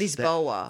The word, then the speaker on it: Lisboa: Okay, well, how do Portuguese people say it? – That's Lisboa.